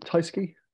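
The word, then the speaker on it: Tyskie: Tyski. Tyski. – It's actually named after what the bottle sounds like. – That's how you pronounce it.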